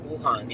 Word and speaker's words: Wuhan: He warned them that there's something crazy going on in that, what is it, how do you pronounce it, Wuhan? – Wuhan, yeah.